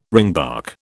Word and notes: ringbark: British English

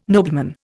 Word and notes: nobleman: Indian

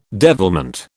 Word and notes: devilment: British English

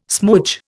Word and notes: smoodge: Indian

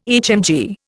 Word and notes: HMG: Indian

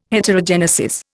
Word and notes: Heterogenesis: Indian

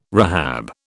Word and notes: rehab: British English

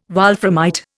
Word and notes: wolframite: Indian

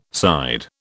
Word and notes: side: British English

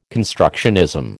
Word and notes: constructionism: American English

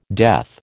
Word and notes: death: American English

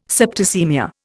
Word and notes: septicaemia: Indian

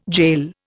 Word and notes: gaol: Indian

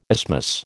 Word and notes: isthmus: American English

